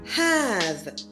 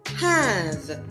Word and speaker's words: have: Pour les francophones, n'oubliez pas de bien prononcer le H, donc vous dites have, mais pas non plus trop fort. Donc vous dites simplement have, have.